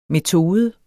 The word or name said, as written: metode